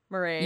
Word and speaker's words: Murray: Murray.